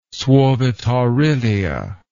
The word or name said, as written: suovetaurilia